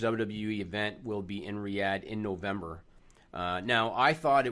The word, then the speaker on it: Riyadh: So and Nigel, maybe you can correct me on the pronunciation. So the capital of Saudi Arabia, is it called Riyadh? – Yeah, Riyadh.